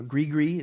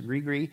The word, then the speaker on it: gris-gris: Now, sometimes they'll use, like you saw, like a bag, again, a gris-gris is actually how it's pronounced, gris-gris.